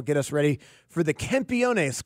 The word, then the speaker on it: Campeones: How do you say it again? Campeones. – Campeones. – Campeones. – Campeones. Yeah, he'll help us with our Spanish. – Yeah, Campeones.